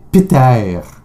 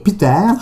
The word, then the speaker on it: Pitter: C'est Peter. Peter. P-I-T-T-E-R. OK. Peter. Peter.